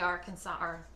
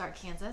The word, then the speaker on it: Arkansas: and then the Great Bend of the Arkansas, mm-hmm. and I should say it properly, it's Arkansas. I'm from Wichita, it's Arkansas River.